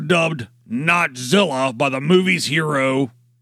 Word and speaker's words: Hiro: His name is spelled H-I-R-O. And is pronounced like the word hero.